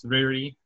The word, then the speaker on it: Riri: There's a lot of mystery that goes on with the main character, and her name is Riri, which is, like, a pun on Lily, because in Japan, Lily would be pronounced Riri.